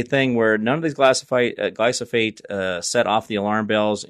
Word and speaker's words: glyphosate: Glyphosate, I think I'm saying that wrong. Glyphosate. There we go.